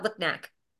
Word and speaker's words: Lipnick: It's but yeah, it sounds just like how it's spelled, Lipnick.